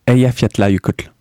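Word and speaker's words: Eyjafjallajökull: Alors tu sais ce que je vais te demander est-ce que, Est-ce que tu peux le prononcer Eya Yukotl.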